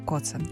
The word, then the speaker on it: Коцен: І сьогодні моєю гостею є Тетяна Коцен. Я вітаю вас. – Вітаю. – Я сподіваюся, що я правильно поставила наголос у прізвищі. – Так.